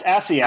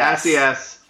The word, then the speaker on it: Assayas: It's actually pronounced Assayas. – Assayas. – Assayas.